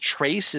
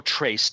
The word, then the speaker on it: Tracz: I think the book by Joe, I believe it's pronounced Trace. Is that correct? Does anyone know? – No idea. – Yes, it is. – Yeah. Yeah. Joe Trace, TRACZ,